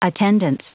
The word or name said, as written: attendance